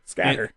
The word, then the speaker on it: SK8R: It's called Street Skater, and it's spelled SK8R. – Scatter. – And I did not know. I just didn't get it, that the 8 was supposed to just be said 8.